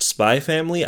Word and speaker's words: Spy x Family: Like, how do you pronounce the X in these? – I believe it's just Spy Family. I've never been clear on how that works either. Spy by Family, Spy Times Family.